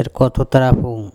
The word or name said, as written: erkOthotarapHong